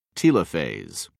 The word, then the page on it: telophase: British English